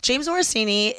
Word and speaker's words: James Morosini: The guy that was on, James Morosini, am I saying it correctly? – Yeah, you okay. – are. – James Morosini he was on Sex Lives of College Girls.